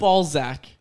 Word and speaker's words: Balzac: Yeah, make sure you pronounce that with the Z there. – Balzac. – It's Balzac.